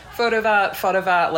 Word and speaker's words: Fotovat: I think people try and overpronounce it a lot of the time, but it is just Fotovat, like, whatever.